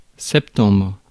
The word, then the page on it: septembre: France; [sɛp.tɑ̃bʁ]